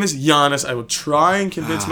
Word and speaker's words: Giannis: I could never bring myself to look at you in the eyes as a grown man and say, Danny, it's not Giannis, it's Giannis. So, sorry on Danny's behalf, he's been saying it wrong. Danny, I know you're going to own up to it. It is Giannis.